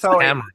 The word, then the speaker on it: Kamara: Tim, you're putting the wrong emphasis on the wrong syllable. Emphasis on the wrong syllable. So it's Camera.